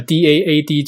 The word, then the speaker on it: DAAD: 那另外来像来德国留学的话，一方面可以申请就是教育部的公费留学，另一方面也可以申请德国的呃 DAAD 奖学金。那一般我们会按照那个德文的念法把它念成是 DAAD。